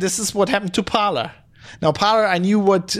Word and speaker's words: Parler: And I think it's called, according to the internet, it's pronounced Parler. Somebody who did French in school, I always want to call it Parler. But since it's American, it's Parler.